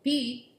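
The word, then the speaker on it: Pi: Their relationship was a roller coaster where she would call him pee if they were on good terms. Not pee like urine. Pee like P-I. That's how you pronounce it in Portuguese.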